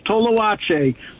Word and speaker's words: Toalache: Every letter you pronounce in order, even though it's and Mexican, we just pronounce it like in English. Toalache.